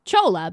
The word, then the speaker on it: challah: Page 74 is a recipe for C-H-O-L-L-A bread. – This is which I'm going to pronounce as chola because I don't know how else you would pronounce it. – Well, no, that's correct for sure.